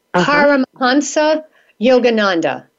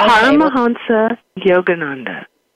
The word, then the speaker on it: Paramahansa Yogananda: When we come back, I want to talk to you about, and I want to make sure I'm pronouncing this correctly, Paramahansa Yogananda. Is that correct? – Yes. Okay. Paramahansa Yogananda.